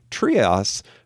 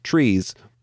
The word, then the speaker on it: Treas: Now, her name is spelled T-R-E-A-S, and forever and a day, I've always thought that that was pronounced Trias. However, in a bonus material that I watched with the producer, Kenneth Johnson, he explained that her name is actually just pronounced Trees.